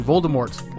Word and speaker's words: Voldemort: You know, when they were making the movies and saying Voldemort like 1,000 times, she didn't bother to say, yeah, by the way, the T is silent. It's Voldemort, not Voldemort.